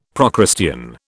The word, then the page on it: Procrustean: British English